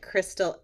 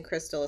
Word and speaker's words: Krystal: I did forget to say um, if anybody is on the gram, you can find me at Krystal M. Harris, and Krystal is spelled with a K.